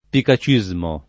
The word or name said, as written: picacismo